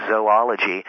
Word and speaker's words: zoology: Um, but also, it is a fact that a lot of people aren't aware that um, the original pronunciation, and in fact, uh, the preferred pronunciation in the field is zoology. Um, not unlike the zo sound of protozoan, for mm-hmm.